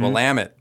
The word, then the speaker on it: Willamette: Willamette, damn it! That's how people remember it.